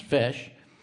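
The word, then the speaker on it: feis: A FESH, pronounced FESH, is the name for an Irish dance competition.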